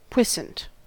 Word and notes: puissant: American English

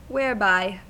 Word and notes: whereby: American English

